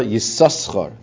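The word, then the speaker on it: Yisaschar (יששכר): So it's interesting because they bring here from the Chassam Sefer that Shapama Rishayna Sheksuva B'Teva Zu B'Teira Yesh lekara yisaschar b'shnei shin k'dei He says the first time that you read the name Yisachar in the Torah, you should pronounce it with two sins.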